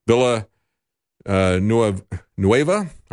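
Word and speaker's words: Villanueva: And one individual who is a former Army Ranger, Villa Nueva, I believe is the way you pronounce his name.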